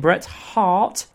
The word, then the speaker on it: Bret Hart: I do pronounce my H's, as in Bret Hart.